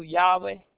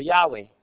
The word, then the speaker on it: Yahweh: Scholars are unsure of the exact pronunciation of the Hebrew for Jehovah. Most scholars agree to Yahweh, or Yahweh.